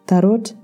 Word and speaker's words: tarot: cái từ đấy viết là tarot nhưng mà đọc là tarot